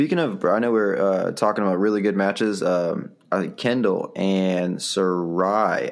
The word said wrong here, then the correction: Sayuri: I believe, from T-Town. I don't know if I'm saying her name right. – Oh, Yuri. – Sayuri, that's Sayuri.